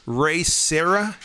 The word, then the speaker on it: Ray Serra: I also loved seeing Ray Serra.